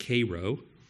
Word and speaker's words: Cairo: We followed it as long as we had time, which turned out to be for us a Cairo, which I learned is actually pronounced Cairo, which is about the southernmost possible part of Illinois.